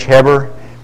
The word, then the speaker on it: Gerber: And Paul, we call him Gerber. I think he said that the G is silent. I think he pronounced it more like an H, Heber.